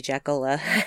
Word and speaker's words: Jackola: And I am on Instagram, but it's under my real name, which is Brandy Jackola. You want to know how that's spelled? J-A-C-K-O-L-A. Not that hard. You're just like, oh, just like it sounds. It sounds Jackola. Just like it's spelled.